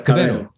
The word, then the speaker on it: Cavero: Cavero? – Cavero. – Okay, perfect. – Yeah, Cavero. – Well, I'm Portuguese, so I can roll those R's.